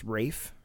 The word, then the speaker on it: Ralph Fiennes: It is Ralph Fiennes. And he's like, isn't it pronounced Rafe? – Ralph?